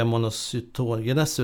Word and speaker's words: monocytogenes: Ja, monocytogenes brukar jag ofta säga. – Det ligger i vardagssnack. – Men jag, de som förstår sig på brukar nog säga någonting annat skulle jag säga. Monocytogenes snarare. – Monocytogenes. – Med lite betoning där i mitten.